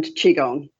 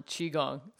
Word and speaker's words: Qigong: It's Tuina and Qigong. – Tuina and Qigong.